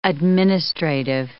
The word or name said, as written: administrative